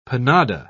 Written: panada

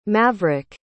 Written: Mavrick

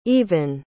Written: eavan

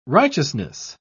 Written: righteousness

